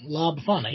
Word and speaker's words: La Befana: La Befana, meaning old witch. I'm saying that right. La Befana, yeah.